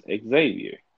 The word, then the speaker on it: Ecksavior: His name was Xavier. Yeah. But it was spelled like E-C-K-S-A-V-I-O-R. Xavier. – Xavier. – Xavier, yes.